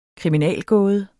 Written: kriminalgåde

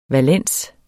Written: valens

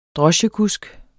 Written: droschekusk

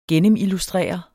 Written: gennemillustrere

